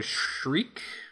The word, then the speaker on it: shrike: He was fighting a, I think it's called a shriek but it's spelled S H R I K E.